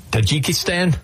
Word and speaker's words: Tajikistan: Tajikistan? – Mm-hmm.